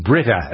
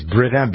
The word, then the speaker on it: Brita: There's another lawsuit along the same lines, Jay, a California man suing Brita. That's how you pronounce the filter company, right? It's Brita, B-R-I-T-A.